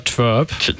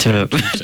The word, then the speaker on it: TWRP: Also ich sage immer Twerp. Also T-W-R-P heißt die. Twerp.